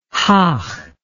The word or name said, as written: haag